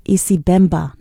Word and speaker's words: Isibemba: Today's New York Slice featured artist is Muriel Mwamba, a New York City-based Zambian artist who sings in several languages, including Isimba. I think I said that right. Isibemba. No, I didn't say it right. So she sings in Isibemba, her native tongue, English, French, and Lingala.